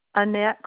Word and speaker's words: Annette: Okay, the first name is Annette, A-N-N-E-T-T-E, and the last name is Collins, C-O-L-L-I-N-S.